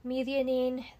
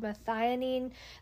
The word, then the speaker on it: methionine: The first being lysine, methionine, that you might also hear it called methionine. There's a couple different ways that that's pronounced.